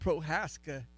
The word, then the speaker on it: Prochaska: His name, and he told me the C is silent in his name. So his name is Tony Prochaska. It's Prochaska, but the C is silent, he said. So we're going with Prochaska, and hopefully I got that right.